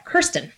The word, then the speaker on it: Kirsten: And I am very excited today to be joined by Kirsten O'Malley. Did I say it right? – You did. – Thank God. – It rhymes with first. – Right. Kirsten.